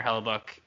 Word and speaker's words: Hellebuyck: So I the think I think the most I think you could have put Connor Hellebuyck.